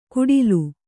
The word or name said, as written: ಗುಡಿಲು